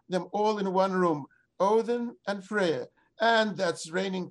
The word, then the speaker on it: Odin: and Odin, that's the usual pronunciation of the Scandinavian god in English, Odin was a weather god, and his dogs, of course, reigning Odin's dogs.